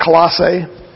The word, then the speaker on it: Colossae: Now, this little tiny town of Colossus, some people pronounce it Colossae. Colossus, it was in the Roman province of Asia, which is now what's called modern Turkey.